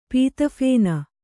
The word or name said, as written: ಪೀತಫೇನ